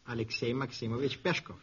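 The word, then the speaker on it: Alexei Maximovich Peshkov: Alexei Maximovich Pieshkov. – Maxim Gorky. – Yes, Maxim Gorky is right. How do you say his real name? – Alexei Maximovich Pieshkov. You did it all right.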